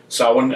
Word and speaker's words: Samhain: Religious scholars agree that the word S-A-M-H-A-I-N is pronounced Samhain, comes from the Gaelic Samhain, but they're divided on whether it means the end or beginning of summer.